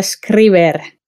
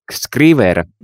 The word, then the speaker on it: Scriver: Mä sanon, että Scriver. – Scriver. – Mä äänän sen ihan suomalaisista.